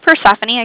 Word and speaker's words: Persephone: My name is Persephone.